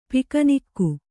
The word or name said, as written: ಪಿಕ್ನಿಕ್ಕು